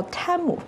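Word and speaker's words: Temu: 因为这个公司啊，之前在上一次的超级碗的广告当中，管自己叫 Tim，那这似乎也变成了一个官方的广为流传的叫法。但是在今年的超级碗的广告当中，他们管自己叫 Tim，所以马上这个这个公司到底怎么读啊，就变成了一个热搜的词条。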